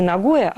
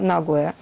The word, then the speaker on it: Нагоя: В Японии делают ударение на первый слог. Не Нагоя, а Нагоя.